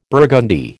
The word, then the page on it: burgundy: American English